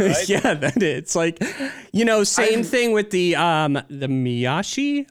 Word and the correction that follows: mawashi: I'm sorry for mispronouncing the Mawashi.